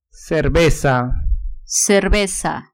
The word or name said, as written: cerveza